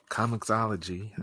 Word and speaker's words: comiXology: Reading comic books on comic comiXology.